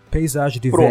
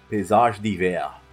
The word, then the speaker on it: Paysage d'Hiver: Eu não sei exatamente a Paysage d'Hiver. – Pronúncia francesa. Paysage d'Hiver.